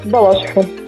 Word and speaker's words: Балашиха: Балашиха. – Балаш. Помоги, пожалуйста, ставить ударение да. – правильно. Вот постоянно путает. Балашиха. Балашиха или Балашиха? – Балашиха!